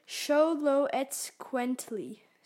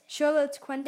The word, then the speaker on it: Xoloitzcuintli: I'm going to say Xoloitzcuintli. – So yeah, but they write it Xoloitzcuintli.